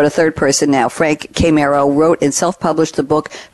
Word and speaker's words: Chimero: And RJ has sent us a quote from Frank and I have the pronunciation here, Chimero, and it's C-H-I-M-E-R-O.